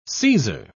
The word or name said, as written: seizer